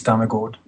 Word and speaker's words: Damegård: And then Damegård, if you change the A for an O, it's Damegård.